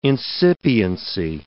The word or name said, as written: incipiency